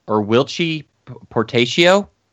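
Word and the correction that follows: Whilce Portacio: I believe it's Wils Portacio. – His real name is William, but yeah, he goes by Wils Portacio.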